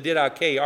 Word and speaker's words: Didache: I have a quote here from the early Christian document called the Didache, which looks as if it's spelled the didache, as if it rhymes with headache, but it's pronounced Didache, which is the acts of the or the teaching of the apostles, rather.